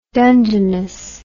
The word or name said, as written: dungeness